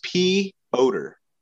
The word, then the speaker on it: Piotr: It's P. I'm not trying to be funny, but it's Piotr. – Piotr.